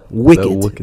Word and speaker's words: wicked: Porque pues la I latina es I, ¿me entiendes? Pero se pronuncia w, wicked. wicked.